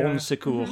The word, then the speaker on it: Bon Secours: I think it's supposed to be pronounced in sort of French. Yeah. Bon Secours. – Mm-hmm. Sounds like it.